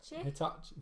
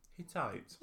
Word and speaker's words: Hittite: And I watched a short YouTube video of the guy going, Hittite. It's like 15 seconds, Hittite, three times.